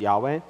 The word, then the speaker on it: Yahweh: Uh, remember the Lord, whenever you see that all caps, that is a personal name of God, which, uh, which people generally understood to be Yahweh, pronounced Yahweh.